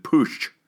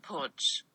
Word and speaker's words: Putsch: How's it, how do you pronounce that? Damn it. – Putsch. – Putsch. Is that right? – Putsch.